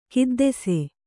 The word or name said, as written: ಖಿದ್ದಿಸೆ